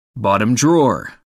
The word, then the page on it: bottom drawer: American English